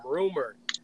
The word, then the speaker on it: RMR: So I'm doing some research and I don't know, people probably don't know who this is, but there's an artist named Rumor. He dropped a song called Rascal. – It's spelled RMR.